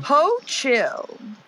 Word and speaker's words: Hochul: Hochul.